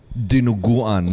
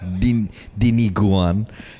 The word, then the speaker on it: Dinuguan: And then the comments were just like, it's Dinuguan, not Diniguan.